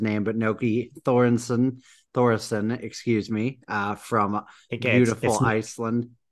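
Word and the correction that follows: Nökkvi: It's Nukvi. It's Nukvi. So like I warned everyone that I would butcher it.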